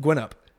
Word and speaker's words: Guynup: Guynup.